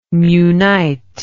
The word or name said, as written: munite